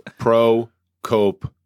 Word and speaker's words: Prokopchuk: Prokopchuk. – Yeah, it's not that hard. It's a hockey last name, hockey-sounding last name, 3 syllables.